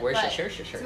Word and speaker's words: Worcestershire: I mean, if you really want to get down to the nitty-gritty of it all, Bloody Mary mix consists of tomato juice, Worcestershire? I have no idea how to pronounce it. – Worcestershire.